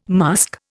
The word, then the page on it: musk: Indian